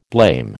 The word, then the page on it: blame: American English